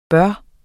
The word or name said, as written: burde